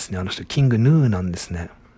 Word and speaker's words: King Gnu: あれ、あの人たちはキング、グヌって書いてキングヌーって読むんですね。